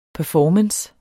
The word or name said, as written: performance